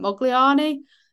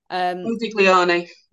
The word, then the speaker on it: Modigliani: going to mispronounce his name, Mogliani, Modigliani.